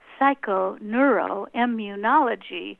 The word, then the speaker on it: psychoneuroimmunology: There's a relatively new field of science, and it's a nine-syllable word that if you go to a party that's boring, it's great to say, what do you think of psychoneuroimmunology?